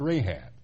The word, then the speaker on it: Rahab: Don't be confused by the C. It's pronounced Rahab.